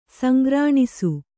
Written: ಸಂಗ್ರಾಣಿಸು

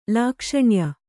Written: ಲಾಕ್ಷಣ್ಯ

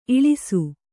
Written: ಈಳಿಸು

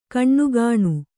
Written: ಕಣ್ಣುಗಾಣು

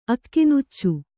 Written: ಅಕ್ಕಿನುಚ್ಚು

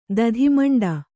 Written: ದಧಿಮಂಡ

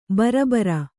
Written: ಭರಭಾರಿ

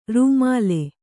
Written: ರೂಮಾಲೆ

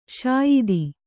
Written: ಸ್ಯೂತಿ

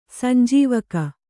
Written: ಸಂಜೀವಕ

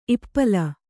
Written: ಇಪ್ಪಾಲು

